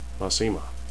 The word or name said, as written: masema